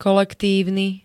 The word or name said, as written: kolektívny